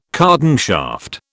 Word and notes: cardan shaft: British English

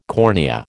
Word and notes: cornea: American English

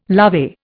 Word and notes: lovey: Indian

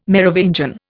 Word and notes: Merovingian: Indian

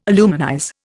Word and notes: aluminize: Indian

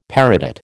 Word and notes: peridot: American English

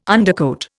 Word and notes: undercoat: Indian

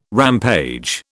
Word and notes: rampage: British English

